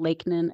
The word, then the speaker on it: Lakenan: It's J-E-N-N-I-E. And then my last name, Lakenan, is L-A-K-E-N-A-N. So N-A-N, like the first three letters of Nancy.